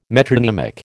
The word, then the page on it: metronymic: American English